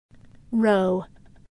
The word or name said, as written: row